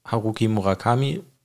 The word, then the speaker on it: Haruki Murakami: Kensaburo Oe und Haruki Murakami.